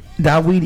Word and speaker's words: Daweed: But that's like the Daweed is actually the East African way of pronouncing David.